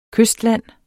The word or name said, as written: kystland